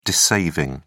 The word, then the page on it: dissaving: British English